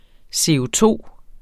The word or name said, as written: CO₂